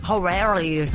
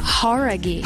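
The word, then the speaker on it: Jauregui: Horaghi, não é? – Horaghi. – Horaghi. – Então a gente estava falando errado, cara.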